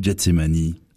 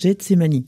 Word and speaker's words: Gethsémané: D'ailleurs, comment faut-il le prononcer ? Gethsémané ou Gethsémané ? – Alors, comme c'est un mot qui vient de l'araméen, on ne sait pas exactement si c'est l'une ou l'autre prononciation. Les Bibles protestantes le traduisent Gethsémané, alors que les Bibles catholiques le prononcent Gethsémani.